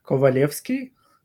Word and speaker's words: Kovalevskij: So my name is pronounced Vsevolod Kovalevskij, and for the shortened version is Seva.